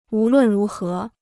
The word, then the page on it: 无论如何: wú lùn rú hé